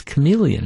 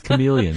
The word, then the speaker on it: chameleon: how do you pronounce this? And they'd say, it's chameleon. And I'd say, but there's an H there. – Yeah. – They say, we know, but it's, trust us, it's chameleon.